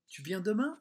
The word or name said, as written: tu viens demain